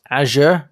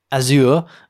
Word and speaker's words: Azure: And also, it's pronounced Azure, not Azure, as I mentioned.